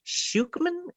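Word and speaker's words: Schucman: written by Helen Schuchman. Am I pronouncing that right, Todd? – Yeah, that's close enough.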